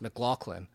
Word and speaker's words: McLaughlin: and they had a G in it because it's pronounced McLaughlin. And not McLaughlin. So he actually, and he said he didn't care because you actually, there's a G sound in there.